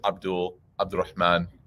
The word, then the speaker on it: Abdurrahman: Like, it's one thing to shorten it because maybe like instead of saying Abdurrahman, right? And I like tell them like, give the ayn, like that. I might say my name is Abdurrahman, just so it's easy for them.